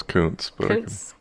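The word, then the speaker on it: Kountze: The dispute began in 2012 when middle school and high school cheerleaders at public schools in Counts, Texas. I'm not sure I'm saying that right. – I think it's Counts.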